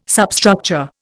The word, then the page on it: substructure: Indian